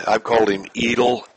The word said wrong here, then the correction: Eitel: Eitel, it's Eitel. Yeah.